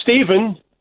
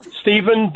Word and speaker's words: Stephen: You're Steve, but do you spell it S-T-E-P-H? – Yes. Well, it's Stephen. Stephen, but the shortened version is Steve. – But do you spell your name with a PH or a V? – I spell it with a PH.